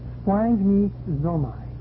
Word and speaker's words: splanchnizomai: The English pronunciation of the Greek word is something like splanchnizomai.